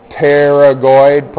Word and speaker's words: pterygoid: These are the pterygoid process, but don't spell it like you pronounce it, right? It starts with a P, right?